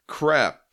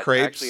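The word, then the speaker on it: crêpe: Crep. It's pronounced like crap. Oh my god. – Crepes.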